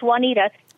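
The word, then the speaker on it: Wauneta: Oneta, Nebraska, if I'm saying that right. What's on your mind today? – Hey, God bless you. It's Juanita.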